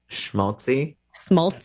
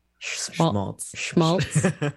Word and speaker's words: schmaltz: Schmaltz. Yeah, it's a sh sound. – Schmaltz.